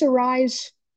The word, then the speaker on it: Arraez: Luis Arraez, I'm saying this correctly,